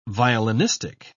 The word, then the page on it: violinistic: British English